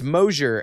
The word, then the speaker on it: Mosier: And then for my last name, it's Mosier. And really the easiest way for me to tell people is it's closure with an M. Like it rhymes with closure, Mosier.